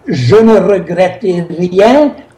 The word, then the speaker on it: Je ne regrette rien: Je ne regrette rien. Kan det vara så? – Je ne regrette rien.